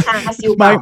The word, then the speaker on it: char siu pau: Tell me about, on the street food tour, tell me about the steam buns, the char siu pau. – Ah, well pronounced, friend. The char siu pau.